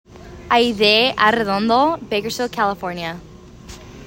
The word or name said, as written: Arrendondo Aydee